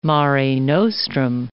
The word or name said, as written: mare nostrum